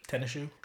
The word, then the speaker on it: Tinashe: What is it, Tinashe or Tinashe? Like, how do you do it? – Tennis shoe. It's like.